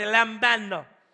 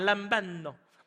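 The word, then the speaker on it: lambano (λαμβάνω): Everybody say lambano. Now say it like you're Italian, lambano.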